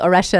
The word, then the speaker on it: Auresia: It's self titled Auresia as well.